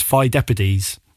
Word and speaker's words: Pheidippides: Now, I'm going to try and pronounce this, but I believe it's Pheidippides. Okay. – I think you've done a stellar job.